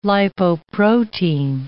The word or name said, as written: lipoprotein